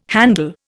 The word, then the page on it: handle: Indian